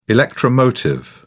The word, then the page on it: electromotive: British English